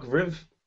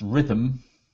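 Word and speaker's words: Rytm: Well, Electron have announced further details of the analog RIV. I guess I'm just making up how I'm saying it now. – Well, it'll be pronounced Rhythm. Oh, no, it isn't, because it hasn't got an M in it.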